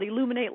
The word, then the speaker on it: Elluminate: E-L-L-U-M-I-N-A-T-E, Illuminate. – Okay. – All right, is their product is called Illuminate Light, so you can only invite 10 people, but, you know, 10 people at $399 is...